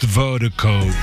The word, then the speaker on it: Virdiko: And Vertigo is spelled V-I-R-D-I-K-O. That's V-I-R-D-I-K-O. Pronounced Vertigo.